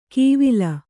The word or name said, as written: ಕೀವಿಲ